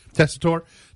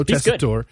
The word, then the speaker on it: Tessitore: That's the Steph Curry joins the team with Rob Riggle and Joe Tessitore? Is that how you say it? – Tessitore. – Joe Tessitore.